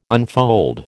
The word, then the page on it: unfold: American English